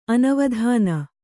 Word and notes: ಅನವಧಾನ: anavadhāna

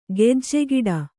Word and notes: ಗೆಜ್ಜೆಗಿಡ: gejjegiḍa

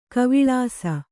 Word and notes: ಗಾವಳಿಸು: gāvaḷisu

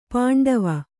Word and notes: ಪಾಂಡವ: pāṇḍava

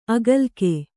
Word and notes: ಅಗಲಿಕೆ: agalike

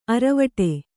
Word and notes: ಅರವಟೆ: aravaṭe

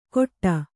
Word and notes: ಕೊಟ್ಟ: kannada

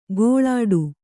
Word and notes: ಗೋಳಾಡು: gōḷāḍu

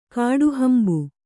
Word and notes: ಕಾಡುಹಂಬು: kannada

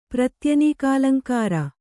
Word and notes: ಪ್ರತ್ಯನೀಕಾಲಂಕಾರ: kannada

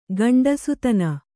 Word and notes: ಗಂಡುಸುತನ: gaṇḍusutana; Kannada

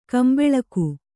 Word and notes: ಗುಂಬಳಕಿ: gumbaḷaki

